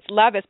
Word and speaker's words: Levis: It's Levis, but it's spelled like Levi.